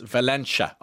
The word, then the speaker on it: Valencia: However, as a Kerry woman, your pronunciation of Valencia is a little off. It's Valencia. Oh, I'm sorry, and not pronounced like the Spanish town.